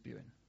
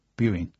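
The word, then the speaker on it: Buan: It's not what, so what is it officially? – I say Luxus, Luxus Buin. – Buin. – Buin, yeah. – Buin. – How do you spell that? – It's B-U-A-N, but because it's Old English, apparently when we looked into Google, it's not pronounced like phonetically.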